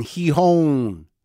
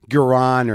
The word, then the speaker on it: Gijón: I'm in Spain. Gijón, G-I-J-O-N, Gijón. Is that right? Gijón. Feels right to me. I just learned that today after saying Gijón for a while and Guran or Gijón.